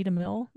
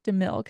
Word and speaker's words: DeMille: But anyway, DeMille, because it's like I'm ready for my close up, Mr. DeMille. That's it.